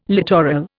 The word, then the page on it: littoral: Indian